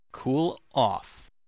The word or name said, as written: cool off